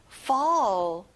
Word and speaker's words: fall: 자, fall이 아니라 입을 길게, fall.